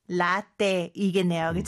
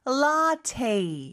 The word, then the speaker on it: latte: 자, 일단 latte는 라떼이겠네요. 그렇죠? 발음이 조금 다르네요. 그렇죠? 영어로는 라테 이렇게 발음을 합니다.